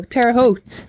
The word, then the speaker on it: Terre Haute: Oh, Terre Haute.